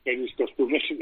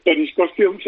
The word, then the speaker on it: Peris Costumes: Pues Peris Costumes está, es Peris Costumes en inglés y Peris Costumes en español. – O sea que perfecto, no lo decimos mal.